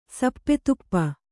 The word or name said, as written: ಸಪ್ಪೆತುಪ್ಪ